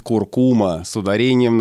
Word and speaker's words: куркума: Да, вот я здесь, человек, который всегда говорит «куркума» с ударением на второе «у» и не может себе представить это слово иначе.